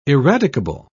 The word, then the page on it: eradicable: British English